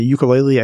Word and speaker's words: ukulele: And I think I mispronounced that wrong too. It's ukulele is the correct pronunciation.